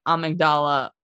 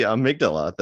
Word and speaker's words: amygdala: Well, amygdala. Thankfully you're saying this because I was going to say amygdala when if I was put to the task of explaining this all, I was definitely going to pronounce it that way. So that would have been scary. – Oh, I would have just ran away. – So it's the amygdala, the fear center of the brain.